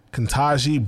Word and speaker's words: Ketanji: I want to shout out Ketanji Brown Jackson. – Oh, that's how you say her name, Ketanji.